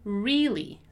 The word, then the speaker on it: really: First of all, we have, once again, that long E sound in the word really. So really, a long E sound, right? Really.